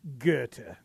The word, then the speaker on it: Goethe: Hey, by the way, anybody know how to pronounce the Chicago street that is spelled Goethe? Goethe. – Goethe. – No, I believe it's Goethe. Based on the German philosopher. I believe the correct pronunciation is Goethe.